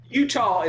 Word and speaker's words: Eutaw: Utah is spelled E-U-T-A-W here, by the way. Utah, Mississippi. Look it up.